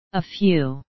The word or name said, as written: a few